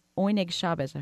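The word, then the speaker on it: Oyneg Shabes: I hope I'm saying that correctly. – Yep. – Can you talk about the significance of the name? – Yeah, it was a code name. Oinig Shabbos in Yiddish means joy of the Sabbath.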